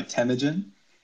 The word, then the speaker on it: Temujin: Yes, I go by Temujin. So you were pretty close with your first guess. – It is you're just landing a little hard on the wrong syllables, but I've heard worse.